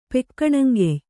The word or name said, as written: ಪೆಕ್ಕಣಂಗೆಯ್